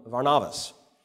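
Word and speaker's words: Varnavas (Βαρνάβας): So God brings in a man named what we call Barnabas. I'm going to call him Varnavas, because that's how you would pronounce it in Greek.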